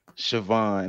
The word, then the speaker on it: Siobhan: It's pronounced Siobhan.